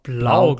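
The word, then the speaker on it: Blough: Jetzt aber ohne Jared Goff, denn der wurde positiv getestet auf Corona und entweder mit David Blah oder wie wird er ausgesprochen? David Blah. – Blau, Blau glaube ich tatsächlich.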